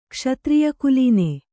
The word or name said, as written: ಕ್ಷತ್ರಿಯಕುಲೀನೆ